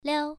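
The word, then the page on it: liao: tone 1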